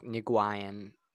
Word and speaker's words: Nguyen: Nguyen, I'm just dropping the N, but it's like Nguyen, okay.